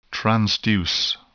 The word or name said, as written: transduce